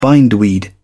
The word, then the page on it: bindweed: British English